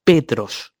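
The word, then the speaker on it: Petros (Πέτρος): Y se pronuncia Petros.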